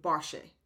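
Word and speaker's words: bashe (باشه): And here is used in a colloquial form, but the real verb is boshad, which is in colloquial which is in colloquial changed to bashe. Bashe. D is not pronounced.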